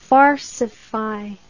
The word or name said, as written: farcify